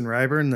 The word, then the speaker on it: Ryburn: So I'm just Justin Ryburn.